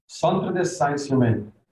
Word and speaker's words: Centre des Sciences Humaines: Centre des Sciences Humaines. – Okay, that's how you pronounce it, not like how I said it.